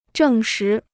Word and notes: 证实: zhèng shí

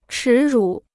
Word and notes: 耻辱: chǐ rǔ

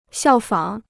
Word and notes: 效仿: xiào fǎng

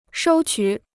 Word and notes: 收取: shōu qǔ

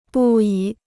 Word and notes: 不已: bù yǐ

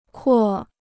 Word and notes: 扩: kuò

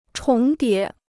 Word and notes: 重叠: chóng dié

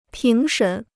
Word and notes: 评审: píng shěn

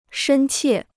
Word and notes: 深切: shēn qiè

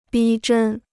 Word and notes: 逼真: bī zhēn

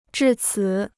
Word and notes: 至此: zhì cǐ

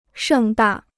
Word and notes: 盛大: shèng dà

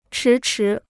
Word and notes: 迟迟: chí chí

